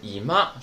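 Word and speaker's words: いま: 那个单词怎么读来着？是不是读成姨妈？哎，那个是姨妈，是起居室，而伊玛是现在，对吧？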